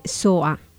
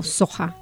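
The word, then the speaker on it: Soha: Soa o Soja, para los que pronunciamos la H, se escribe S-O-H-A, y ella se define como una cantante franco-latina que no es, digamos, de ningún lugar, ¿no?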